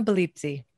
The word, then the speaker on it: Belizzi: Karina Belizzi. That would Belizzi. Be the, in Italy. – Okay. – Here in the States, we butcher it a bit. It's Karina Bell, like to ring a bell. Izzy? – Okay.